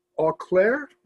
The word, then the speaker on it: Eau Claire: Eau Claire, I believe is, it, is how it's pronounced, University of Wisconsin La Crosse, and then Wesleyan University.